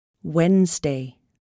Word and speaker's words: Wednesday: The letter C is not pronounced in the ending S-C-L-E, muscle. The letter D is not pronounced in these words. Handsome, Wednesday.